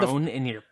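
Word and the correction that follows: Schrödinger's: I was trying to say Schrodinger's cat, but mangling the pronunciation.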